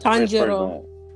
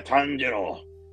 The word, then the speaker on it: Tanjiro: Tanjiro? How do you say it? – Tanjiro. – Yeah, Tanjiro.